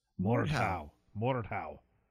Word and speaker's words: Mordhau: Mordhau.